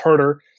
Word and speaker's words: Huerter: That's G-U-D. Good. Why does Kevin Herter's last name pronunciation change every day? – It doesn't. It's just people have no idea who he is, and so they see Huerter or whatever they want to see, and so that's how they pronounce it. But it's Huerter. The E, the first E is silent for whatever reason, and it's just Huerter.